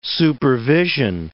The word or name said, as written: supervision